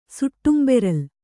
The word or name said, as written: ಸುಟ್ಟುಂಬೆರಲ್